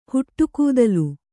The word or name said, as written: ಹುಟ್ಟುಗೂದಲು